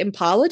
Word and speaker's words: Impala: And before we get started, and this will probably get cut, I've just realized we say Impala differently.